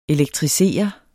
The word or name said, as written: elektrisere